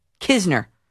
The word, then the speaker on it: Knizner: It's spelled K-N-I-Z-N-E-R. Knizner. Believe me, I went back on all the videos, all highlights, and I found one, and that's how the announcers pronounce his name. Knizner.